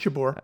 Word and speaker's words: Jabeur: I remembered Ons Jabeur. Is that how you pronounce her name? – That's right, Jabeur.